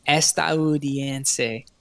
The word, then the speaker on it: Estadounidense: Estadounidense.